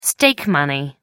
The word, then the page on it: stake money: American English